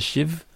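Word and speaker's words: Sidh: The band is called, I think it's pronounced the Shiv. It's S-I-D-H. That's how it's spelled.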